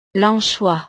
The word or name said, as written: l'anchois